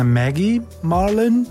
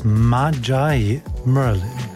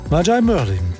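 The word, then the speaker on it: Magi Merlin: Vorname schreibt sich so wie eine bekannte Würzsoße namens Maggie, nur mit einem G. Ist das jetzt eine Maggie Marlin? Nein, nein. Diese hier, die spricht sich aus Majai Merlin.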